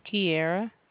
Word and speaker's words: Kiara: Kiera. – How do you spell it? – K-I-A-R-A.